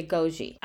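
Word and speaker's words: Agojie: On the pronunciation, I thought they pronounced it in the movie Agogie. – I think they're kind of doing a diphthong at the end.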